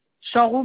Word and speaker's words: Chanroux: Chanrou Marie-Hélène. – Ça s'écrit comment? C-H? – C-H-A-N-R-O-U-X. – A-N-R-O-U-X.